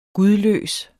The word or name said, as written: gudløs